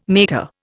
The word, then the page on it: mater: Indian